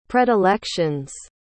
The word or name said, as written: predilections